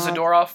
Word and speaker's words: Zadorov: Well, my philosophy on that is that Varlamov made a huge deal over where you put the accent in Russian. So I'm following that same thing. So it is Zadorov for sure.